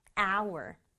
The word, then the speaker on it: hour: Hour. Hour이라는 말이 있어요. 여기 보면 H로 시작을 했지만 H 소리 없고요. 그냥 발음은 hour.